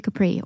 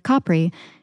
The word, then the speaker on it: Capri: specifically Capri or Capri, if anyone who speaks Italian is listening. I feel like you sound like a douche if you pronounce it correctly, but Italians think you sound like an idiot if you say it like Capri pants, so you truly can't win.